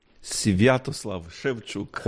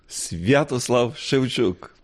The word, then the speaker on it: Sviatoslav Shevchuk: Nos recibe literalmente en su casa su beatitud Stanislav Shevchuk. ¿Lo he dicho bien? – Sviatoslav Shevchuk. – Oh, no he dado ni una. ¿Cómo es? – Sviatoslav Shevchuk. – Sviatoslav Shevchuk. – Perfecto.